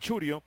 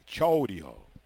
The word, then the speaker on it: Chourio: En inglés le llaman Churio, pero él le gusta que le digan su pronunciación completa en español, que la O y la U se pronuncien debidamente. Chourio.